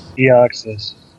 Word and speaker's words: Deoxys: So it, it all leads to a cosmic clash between Mega Rayquaza and I don't know how to say it, but Dexus or Deoxys. – Deoxys.